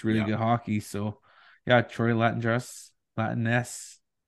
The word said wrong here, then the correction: La Jeunesse: La Jeunesse. La Jeunesse. – It's a soft J.